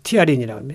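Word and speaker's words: ptyalin: 여긴 피티아린이라고, 이제 피는 이제 묵음이지만 이제 쓰면 이제 피라고 푸라고 발음할 수 있겠지만 이제 그 보통 티아린이라고 합니다. 그 침샘, 예, 침샘에서 티아린이라는 그런 그 효소가 있어요.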